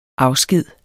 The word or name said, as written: afsked